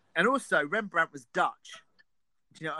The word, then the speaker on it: Rembrandt: Rembrandt, that's it.